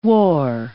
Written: wore

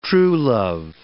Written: truelove

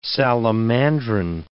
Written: salamandrine

